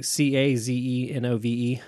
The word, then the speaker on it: Cazenove: Dude, yeah, Christopher Cazenove is the Cazenove. – Right, that's how you say it.